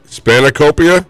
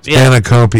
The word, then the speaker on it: spanakopita: Oh, the pita, the spinaca pita. How do you say that? – That's what I was going to say, spinaca or whatever. Spanakopita? – Spanakopita, yes. – Number 6. – That's how you say it.